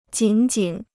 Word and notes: 仅仅: jǐn jǐn

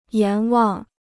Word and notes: 阎王: yán wáng

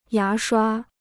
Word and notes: 牙刷: yá shuā

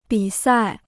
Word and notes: 比赛: bǐ sài

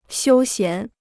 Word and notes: 休闲: xiū xián

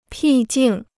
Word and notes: 僻静: pì jìng

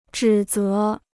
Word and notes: 指责: zhǐ zé

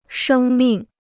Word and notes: 生命: shēng mìng

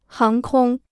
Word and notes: 航空: háng kōng

